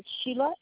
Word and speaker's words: Sheila: Okay, Sheila, S-H-E-I-L-A. – Last name? – Yeah, I'll spell it. It's L-E-T like Tom, W-I-N like Nick, I-U-K.